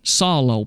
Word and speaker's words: psallo: The Greek word that is translated making melody is the word solo, P-S-A-L-L-O. The P is silent, solo, P-S-A-L-L-O.